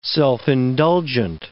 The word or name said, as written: self-indulgent